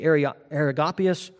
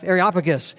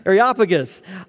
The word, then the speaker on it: Areopagus: Paul was standing in the midst of the Areopagus. I'm not saying that right, am I? Thank you, Jeff. Areopagus. It was not coming out. I needed help this morning. Thank you. Areopagus.